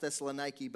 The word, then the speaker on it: Thessaloniki: And it's a coastal town in Greece, and Thessalonica, it's actually pronounced Thessaloniki,